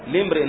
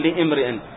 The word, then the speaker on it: لامرئ: لامرئ، لامرئ هكذا تنطق، لامرئ لا نقول لامرئ، لامرئ، لامرئ، أين الهمزة لامرئ؟